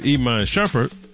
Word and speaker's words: Iman Shumpert: Is somebody I think a lot of people did hear is Iman Shumpert. I'm sure I'm saying his name wrong. I never could pronounce it. – That sounds about right.